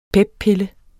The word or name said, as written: peppille